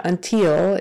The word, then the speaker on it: until: Uh, S sound in the middle, S sound on the end pass short short a sound passports or or passports until until that last syllable has a short i in it it's not until it's until until eh,